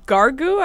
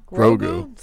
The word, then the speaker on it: Grogu: We have Gargu. I think that's how you say it. And the Mandalorian. – Grogu? – Grogu.